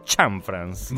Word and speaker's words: Cianfrance: E ho scoperto che, contrariamente a quello alle mie aspettative, si pronuncia Cianfrance.